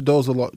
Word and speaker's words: Dolezal: Rachel Dolans? The one from California that pretended to be black, but she was white? – Rachel Dolezal. Rachel Dolezal.